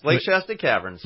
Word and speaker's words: Lake Shasta Caverns: Did I say that right? – Yes, Lake Shasta Caverns.